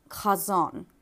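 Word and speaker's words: chazon (חזון): And chazon starts with a letter that doesn't even exist in English. So you pronounce it, obviously, by making that, you know, hacking noise in the back of your throat like you're, you know, getting ready to spit.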